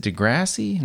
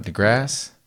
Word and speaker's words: deGrasse: Is it Degrassi? No, DeGrasse. What is it? – How do you pronounce DeGrasse? DeGrassi. That's a show Drake was on.